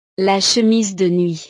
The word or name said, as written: le chemise de nuit